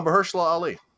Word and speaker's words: Mahershala Ali: Oh, Mahershala Ali.